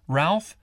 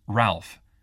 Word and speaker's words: Ralph: It's Ralph Sayers. – How do you spell it? – My first name's Ralph. R-A-L-P-H. – And your last name? – Sayers. That's S-A-Y-E-R-S.